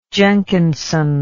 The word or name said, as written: jenkinson